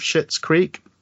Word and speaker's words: Schitt's Creek: I've just watched like, I think it's 4 or 5 series of Schitt's Creek. It is just pronounced that way. There's no way of getting around it.